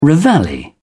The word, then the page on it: reveille: British English